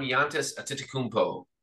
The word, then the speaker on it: Giannis Antetokounmpo: The Cavs select one Giannis Antetokounmpo out of Greece.